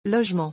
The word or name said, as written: logement